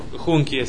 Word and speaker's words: junkie: Sí, medio como medio punk, medio yankee. Pero no de yankee de norteamericano, junkie se pronuncia.